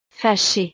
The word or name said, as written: fache